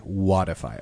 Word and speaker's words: WODIFY: How do you spell it? – W-O-D-I-F-Y. – W-O-D-I-F-Y, Wattify.